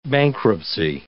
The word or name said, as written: bankruptcy